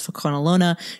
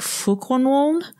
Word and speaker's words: Fokonolona: And the word for a group of people that I pronounced as Fokonolona should have been more like Fokonwon.